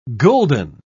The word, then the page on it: gulden: British English